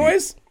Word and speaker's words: Illinois: Illinois? – Yeah, Illinois with an S. No, don't pronounce the S. I will reach across this table and slap both.